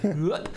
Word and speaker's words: Rupp: Rupp. So think Roy Hodgson and you'll pronounce it right.